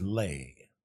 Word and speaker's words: Leigh: L-E-I-G-H, right? – I would pronounce it Leigh. – Well, Janet Leigh. – How do you pronounce S L E I G H? – I don't know. I have to see it written down. Oh, like I don't care. – Sleigh bells. So it's Lay.